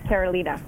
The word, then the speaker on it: Carolina: Yes, it's Carolina.